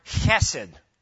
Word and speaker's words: chesed: It's the Hebrew word chesed. It's, if you want to know how to pronounce it, it's the same as my first name in Greek. It's that guttural ch sound.